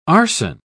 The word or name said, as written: arson